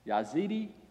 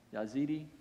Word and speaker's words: Yazidi: It's spelled like this. Some of you will remember this. It's this group. Y-A-Z-I-D-I. Yazidi? Yazidi? Okay. I must call you Yazidi.